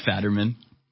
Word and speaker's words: Fetterman: It's pronounced Fetterman.